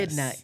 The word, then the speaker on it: echidna: Echidna, E-C-H-I-D-N-A, but it's pronounced Echidna.